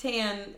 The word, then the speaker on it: Tan France: Super, it's like T-R-A-N. Tran? – Tan France. – That's it. Not Tran. – Sorry. – Tan. – Tranny is what it... No, it's not Tranny. – No, it's Tan is his name, I believe.